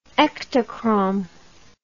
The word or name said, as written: Ektachrome